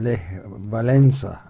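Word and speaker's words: Lech Wałęsa: E, eski bir tanıdık sima da Leh Valensa Valensa diye okunuyormuş.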